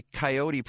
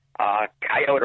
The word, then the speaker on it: coyote: It's called the Cook County, Illinois Coyote Project. Now, how do you pronounce it? – Coyote or coyote are both acceptable.